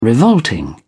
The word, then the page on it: revolting: British English